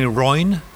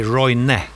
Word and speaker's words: Roine: Uh, I'm, I'm I'm getting used to people call me Roin. Roin. It's actually in Sweden we say Roine. – So you pronounce right. the E at the end.